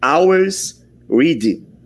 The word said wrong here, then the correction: Always Ready: É isso? Always Ready. Muito bem.